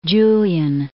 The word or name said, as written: Julian